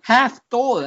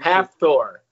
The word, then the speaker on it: Hathor: Hathor. I think it's pronounced like half of Thor. Like Hathor.